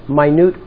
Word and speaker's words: minute: But let's say it correctly. It's a minute order. Minute. The word minute means small. Very small, right.